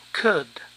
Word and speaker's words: could: Can cambia a could. La L no se pronuncia.